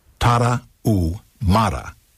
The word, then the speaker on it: Tarahumara: The Tarahumara, pronounced Spanish-style by swallowing the H, Tarahumara, may be the healthiest and most serene people on Earth, and the greatest runners of all time.